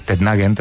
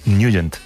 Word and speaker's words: Nugent: Hombre, siempre lo hemos llamado en el barrio Tednagent o Tednagent. – Claro, pero, yo era de Tednagent. – Pero la pronunciación correcta es Nugent.